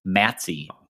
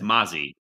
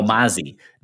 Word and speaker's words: Mazzy: For some reason, it's not pronounced Matsy, which would rhyme with Mazzy. – Oh, Mazzy, not Matsy, like Nazi.